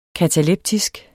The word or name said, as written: kataleptisk